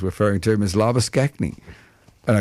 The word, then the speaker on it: Labuschagne: But the correct pronunciation seemingly is Labuschagne.